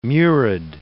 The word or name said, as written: murid